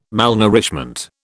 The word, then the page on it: malnourishment: British English